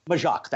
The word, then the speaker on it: Majok: Majok, that's correct.